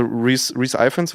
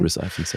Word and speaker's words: Rhys Ifans: Also Rhys Ifans wird er ausgesprochen. – Rhys Ifans, ja.